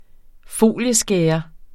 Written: folieskærer

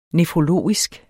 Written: nefrologisk

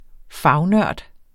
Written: fagnørd

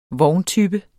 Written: vogntype

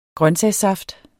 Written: grøntsagssaft

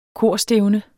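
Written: korstævne

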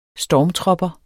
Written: STORMTROPPER

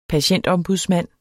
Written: patientombudsmand

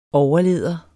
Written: overlæder

